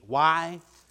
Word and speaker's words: Yahweh: That word translated into English characters, I am, in the Old Testament, is translated by the characters in English, YAHWEH, and spell a word that we pronounce Yahweh.